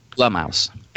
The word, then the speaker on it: Blumhouse: Blumhouse. – Blum. Like rhymes with glum.